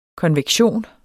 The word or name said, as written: konvektion